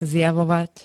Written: zjavovať

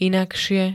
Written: inakšie

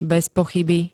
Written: bezpochyby

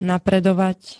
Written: napredovať

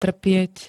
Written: trpieť

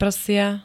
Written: prsia

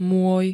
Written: moj

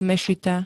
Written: mešita

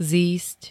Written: zísť

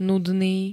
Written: nudný

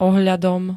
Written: ohľadom

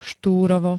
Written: ŠTÚROVO

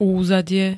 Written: úzadie

